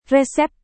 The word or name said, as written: resep